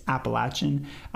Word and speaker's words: Appalachian: Um, but I just have to stand my ground and say the correct pronunciation is Appalachian. Um, but this guy could have been correct, but people were not having it.